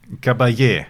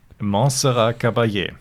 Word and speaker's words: Montserrat Caballé: Montserrat Cabal. Nein. – Caballé. – Caballé. Bitte nochmal als Ganzes zum Reinschneiden. – Montserrat Caballé.